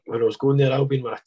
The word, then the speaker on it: Albion: Yeah, Albion.